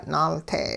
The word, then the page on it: naalte': hupa